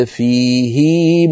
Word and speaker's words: فيه: نرى على اللوحة، لسكون ما قبل الهاء، وفيها صلة وهي ويخلد فيه مهانا في الآية 69 من سورة الفرقة. إذن هكذا صواب نطق هذه الآية ويخلد فيه مهانا.